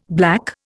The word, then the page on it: black: Indian